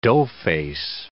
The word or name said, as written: doughface